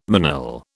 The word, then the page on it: manille: British English